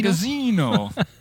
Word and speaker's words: Magazzino: Now, is that how you say it? Magazzino? – Magazzino.